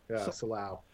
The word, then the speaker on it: Silao: Silao.